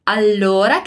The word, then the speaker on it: allora: Allora quer dizer então, beleza? E aqui a gente tem uma dupla consonante no L, né? Um duplo L aqui. Então, aqui a gente vai demorar ali na pronúncia.